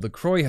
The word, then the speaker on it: LaCroix: Called LaCroix. And I think you would actually pronounce it La Croix. It means the cross, but whatever.